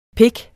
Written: pik

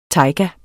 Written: tajga

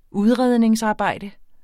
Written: udredningsarbejde

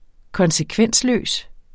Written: konsekvensløs

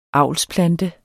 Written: avlsplante

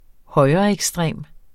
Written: højreekstrem

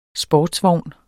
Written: SPORTSVOGN

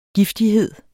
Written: giftighed

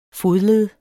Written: fodled

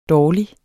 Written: dårlig

